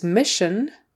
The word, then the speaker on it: mission: Another SH sound in the middle there, even though it's spelled with an SS, but we still have that. Um, well, it's spelled a little differently, but we have S I O N, mission, mission.